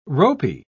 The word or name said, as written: ropy